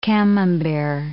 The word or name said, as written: Camembert